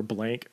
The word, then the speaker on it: Blanc: Subtitle: The Surprisingly Short History of Heterosexuality by Hanne Blanc is how I'm going to pronounce her name. H-A-N-N-E and then Blanc or Blank, B-L-A-N-K. I'm just going to call her Hanne Blanc.